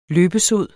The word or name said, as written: løbesod